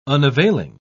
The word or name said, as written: unavailing